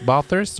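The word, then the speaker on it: Blathers: Bathers, hvordan uttaler du det? det sånn som du sier det, tror jeg. er Helt riktig.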